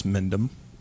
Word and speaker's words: Mendheim: Well, my name is Harris Mendheim. – Heim. – Pronounced Harris Mendheim in Dothan, Alabama.